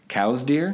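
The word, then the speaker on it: Coues deer: I know you've hunted the cow's deer. Mm-hmm. Did I pronounce that right? – Yes, you have.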